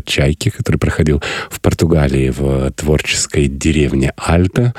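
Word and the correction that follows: Alte: Алте, правильно произносить, да.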